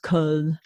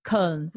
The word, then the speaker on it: Köln: And I decided after about 9 months, I moved to Cologne, which is pronounced Köln. – Oh, say it again. – And it's got an umlaut. It's not one with an umlaut. It's Köln.